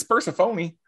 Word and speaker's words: Persephone: What? Persephone. It's Persephone.